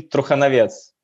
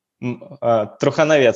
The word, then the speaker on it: Truchanovets: So in Belarus, we pronounce it Maxim Truchanovets. Truchanovets, second name. So in English manner, you can pronounce it Truchanovets. So first part of it, it's true.